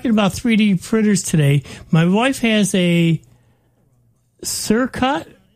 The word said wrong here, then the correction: Cricut: automated... – Uh-oh, Cricut. A Cricut. Yep. – How's it pronounced? How's it spelled? – C-I-R-C-U-T, automated paper cutter to make scrapbook stuff.